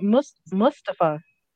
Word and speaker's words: Mustafa: It sounds like Mustafa.